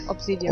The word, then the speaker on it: Obsidian: Ja, Obsidian.